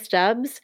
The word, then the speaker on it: Stubbs: Um, I'm on Instagram um, at Vika Stubbs.